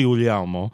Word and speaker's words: Guglielmo: It's Guglielmo or Guglielmo. My grandfather used to answer the phone Guglielmo. In Italian, it's really Guglielmo, which translates into American to Guglielmo Williams.